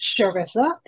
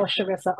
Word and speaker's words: Charissa: My name is pronounced Charissa. Just want to let you know, Charissa Owens.